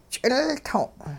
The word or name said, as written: ch'ilto'n